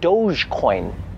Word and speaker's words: Dogecoin: Now, when I'm out, I don't have to worry about some loser trying to talk my ear off about his doggy coin. – Actually, Dulce, it's pronounced Dogecoin.